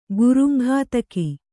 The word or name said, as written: ಗುರುಂಘಾತಕ